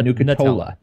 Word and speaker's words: Nucatola: I think it's Dr. Nucatella. I believe that's how you pronounce it. Yeah, Nucatola.